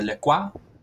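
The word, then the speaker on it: La Croix: You see, Donovan, you mispronounced it before because it is a LaCroix.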